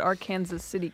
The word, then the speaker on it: Arkansas City: They're on the Kansas side of the Arkansas River, so they pronounce it Arkansas City, Kansas.